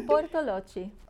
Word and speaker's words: Bortolotti: Bortolotti.